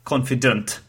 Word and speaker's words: confident: I pronounced it with ant, but it should be unt, confident. So instead of a sound, there is a sound.